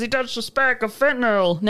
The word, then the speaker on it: fentanyl: He later found out it was fentanyl, which fentanyl. – Yeah, it's pronounced fentanyl. – Oh, is it? – Yeah. – Yeah, it's fentanyl.